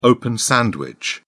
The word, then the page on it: open sandwich: British English